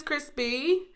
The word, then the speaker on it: Chrispy: Hey, my name is Crispy. And that's spelled C-H-R-I-S-P-Y.